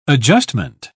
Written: adjustment

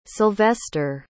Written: Sylvester

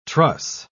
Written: truss

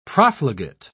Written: profligate

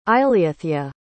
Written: eileithya

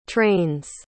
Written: trains

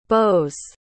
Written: beaus

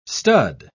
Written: stud

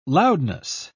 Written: loudness

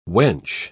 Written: wench

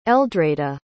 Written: eldreda

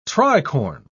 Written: tricorne

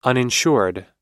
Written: uninsured

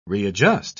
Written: readjust

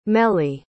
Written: meli

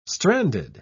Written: stranded

